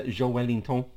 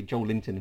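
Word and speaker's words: Joelinton: Joel Wellington, because people say Joel Linton and he corrected them.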